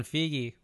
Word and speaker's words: Feige: It's pronounced Kevin Feige. We always pronounce it Kevin Feige.